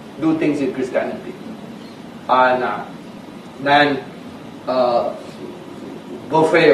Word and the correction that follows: Buffett: or whatever, how you pronounce his name? Buffett, thank you.